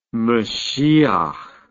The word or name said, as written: mashiach